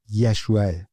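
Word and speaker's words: یشوعه (ישוע): عیسی مسیح، تلفظ درست نام عیسی در عبری یشوعه، همون یشو، همون نامی که در عهد عتیق برای یوشع به کار رفته.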